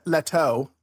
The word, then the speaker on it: Leto: One is, are you two aware that Jared Leto or Leto or however you pronounce that? – Leto. – Jared. – Leto.